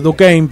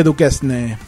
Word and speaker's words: Duquesne: Duquesne, ahora me acuerdo, Duquesne, pero se escribe Duquesne.